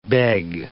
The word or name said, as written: beg